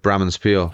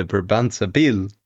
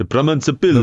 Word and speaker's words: Brabantse Pijl: Mate, I don't even know how you're supposed to say it. I call it Bram and Spiel. – Okay. But let's ask the winner. – In the Belgian way, it would be the Brabantse Pijl. – The Brabantse Pijl. – The Brabantse Pijl. – Yeah, that's it.